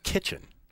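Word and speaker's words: Kichen: And it's just kitchen. Like the room in your house.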